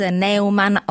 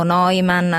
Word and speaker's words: Neuman: Grazie allora ad Andrés Neumann o Neumann, abbiamo sentito che si pronuncia in vari modi, autore ora in Italia di Parlare da soli, pubblicato da Ponte alle Grazie.